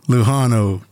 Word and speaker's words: Lujano: The J is an H like in San Jose. – All right. Lujano, go ahead.